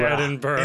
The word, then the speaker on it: Edinburgh: Say Edinburgh, Edinburgh because you're Edinburgh.